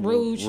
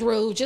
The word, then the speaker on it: Rouge: Rogue, don't look that up because Rogue 34. – Oh, is Rogue or Rogue? – Either one. Rouge. – Rouge the Bat. We've been saying it wrong as hell. – It's Rouge. It's like the makeup.